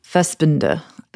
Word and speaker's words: Fassbinder: It's a movie from the early 1970s, a Fassbinder movie. Did I say that right, guys? – Fassbinder.